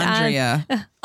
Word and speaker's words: Andrea: And Andrea.